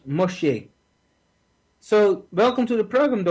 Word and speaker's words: Moshe: And before we had Moshe, is how we pronounce it, means Moses in Hebrew. Before we had Moshe, another family had him for 40 years.